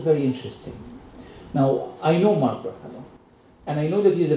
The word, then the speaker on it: Mark Ruffalo: so uh, Mark Ruffalo, a really famous Hollywood celebrity, tweeted yesterday. Did I say it correctly? Mark Ruffalo, right? Yeah.